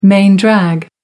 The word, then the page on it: main drag: American English